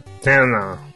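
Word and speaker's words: Thena: E ela vem, então, para interpretar uma personagem uh, Tena, será que é assim que pronuncia? Tu que é o especialista. Tena. Isso.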